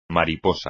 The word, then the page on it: mariposa: Spanish